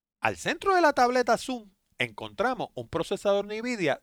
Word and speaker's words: Xoom: El pasado jueves la compañía Motorola anunció su nueva tableta Zoom, que by the way se escribe X-O-O-M.